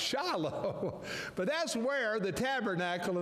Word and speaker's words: Shiloh: At this time, the Ark was in a city called, we pronounce it Shiloh. In Israel, they pronounce it Shiloh. You know, when you put the emphasis on a different syllable, it makes the word sound funny.